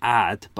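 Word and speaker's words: Odd: You've got Ud Peter Magnusson. I apologise. I know it's Ad, but I find that difficult to pronounce and it sounds awful.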